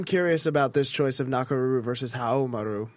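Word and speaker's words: Nakoruru: Nakaruru. Samurai Showdown without the W. – Wait, say it for me again. – Nakaruru.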